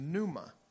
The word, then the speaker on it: pneuma: Well, in Matthew chapter 5, verse 3, the word spirit here in the Greek is pneuma. It's spelled P-N-E-U-M-A, pneuma.